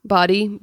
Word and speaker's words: Badde: If people want to learn more, Paul Bada, you know how to say his last name. Is it Bada? – Body, B-A-D-D-E.